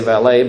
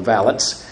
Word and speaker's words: valet: we'd say valet, but they didn't like the French pronunciation, so the British called them valets.